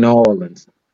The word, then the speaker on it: New Orleans: In New Orleans. Did I say it right? – Okay, New Orleans. – Yes, you did.